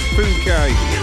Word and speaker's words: Phuket: And let's not read it out the way it's spelt. We've not long started, have we? So, I think it's Phuket.